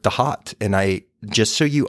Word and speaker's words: Thoth: And, you know, I was recently came across Damien Eccles' work, and he really claims that Thoth should be pronounced the hot.